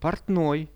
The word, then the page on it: portnoi: Russian